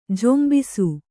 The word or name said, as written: ಝೊಂಬಿಸು